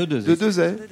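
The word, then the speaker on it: Desaix: Statue équestre réalisée par Bartholdi et la statue de Dezay. – De Dezay. – Et on ne prononce pas le X à la fin.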